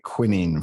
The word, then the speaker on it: quinine: I mean, it came because the Fever Tree is the tree that you get quinine from. And quinine or quinine, as I know it's pronounced in America, is the sort of very essence of tonic water.